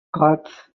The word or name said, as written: scots